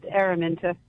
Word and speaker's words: Ermenta: It's an old Hebrew name, so I get called Aaron a lot, and I just go with it. I'm 46 years old, so I go with it. But yeah, it's pronounced Ermenta.